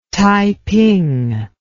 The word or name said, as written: Taiping